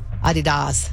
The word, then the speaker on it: Adidas: Do you remember the right way to say that? – Adidas.